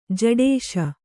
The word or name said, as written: ಝಡಿಸು